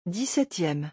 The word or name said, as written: 17eme